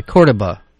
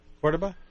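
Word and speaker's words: Córdoba: I want to remind our listeners that we're speaking, and I think I keep mispronouncing. It's the Cordoba. – Cordoba? Uh-oh. – Cordoba Institute. – You can say it either way.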